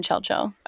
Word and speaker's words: Chelchel: No, it's okay, like literally O and then K and then Chel-Chel. Okay, Chel-Chel. – Chel-Chel. C-H-E-L-C-H-E-L? – Yes. – Okay, Chel-Chel. Like that. You have to say it like that.